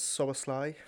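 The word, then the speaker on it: Szoboszlai: The name that I never pronounce right. – Oh, it's Soboslai from...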